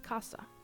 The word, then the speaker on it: caza: But if I said them using the distinción pronunciation, you can tell the difference between the words very easily. So the word for house is casa, spelled C-A-S-A, and the word for hunting is caza, spelled C-A-Z-A.